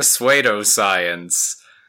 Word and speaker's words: pseudoscience: He said the P was silent. And when I looked at it, I read it as suede. So I've been saying pseudoscience forever.